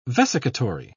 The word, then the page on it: vesicatory: British English